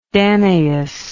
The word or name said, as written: danaus